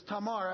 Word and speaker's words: Tamar: I've pronounced her name 20 different ways. It's Tamar, actually.